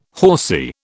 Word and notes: horsy: British English

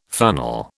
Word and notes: funnel: British English

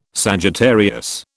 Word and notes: Sagittarius: British English

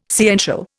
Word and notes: sciential: Indian

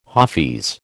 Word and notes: hafiz: American English

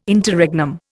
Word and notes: Interregnum: Indian

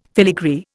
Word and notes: filigree: Indian

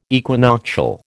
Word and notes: equinoctial: American English

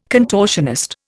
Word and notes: contortionist: Indian

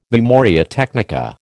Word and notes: memoria technica: American English

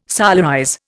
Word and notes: solarize: Indian

